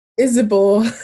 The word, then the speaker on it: Isibor: Isibor.